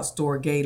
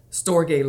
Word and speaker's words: storge: We're talking today about storge love. Rhymes with agape love, S-T-R-O-G-E.